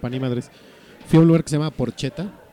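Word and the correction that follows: Porchetta: No es cierto, Porqueta. Se escribe Porcheta con doble T.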